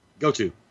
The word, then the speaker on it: Goto: Goto. – I don't know why I'm doing that. Goto.